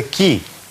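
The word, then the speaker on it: qui: Это читается ки. Ки значит кто? – Вы.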